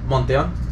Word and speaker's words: Monteon: Monteon.